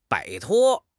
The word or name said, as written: 摆脱